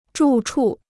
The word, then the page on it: 住处: zhù chù